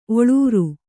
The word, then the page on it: ಒಳ್ಳೂರ್: oḷḷūr; kannada